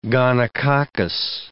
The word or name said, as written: gonococcus